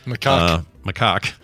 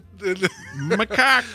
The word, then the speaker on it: macaque: Also known as a crab-eating or long-tailed macaw. – Macaque. Come on now. – I wanted to make that right. – I know. – Away. – You wanted to try to pronounce it. – Macaque.